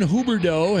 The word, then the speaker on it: Huberdeau: I'm going to take Jonathan Huberdeau. Has he been picked yet? – No. – Did I say that right? – You did say that.